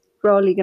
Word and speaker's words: Crowley: Crowley. Mal, Crowley, genau.